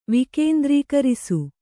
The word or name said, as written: ವಿಕೇಂದ್ರೀಕರಿಸು